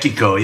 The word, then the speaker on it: Zimbaeko: Is one of your tunes Zimbaeko? How do I pronounce that? – Zimbaeko, yeah, yeah.